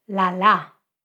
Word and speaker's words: lala: So lala so, lala. Achte darauf, die Betonung von lala kommt auf der zweiten Silbe, also hinten im Wort.